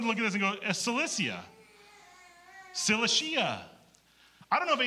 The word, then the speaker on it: Cilicia: Well, I was like, Cilicia. It's Cilicia, by the way.